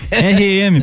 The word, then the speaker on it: R.E.M.: Orra, como é que fala isso aí, cara? RM. RM, pessoal. Quem não conhece, Ren.